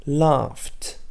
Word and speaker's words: laughed: To laugh devient laughed. Par exemple, dans le verbe to laugh, la dernière que l'on prononce est un F. C'est pour ça que la terminaison ed va se prononcer t. Mais si vous regardez à l'écrit, laugh s'écrit L-A-U-G-H.